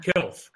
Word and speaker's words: Kecalf: And now I'm going to tell you it's spelled K-E-C-A-L-F. And it's pronounced Kelf.